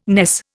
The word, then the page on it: ness: Indian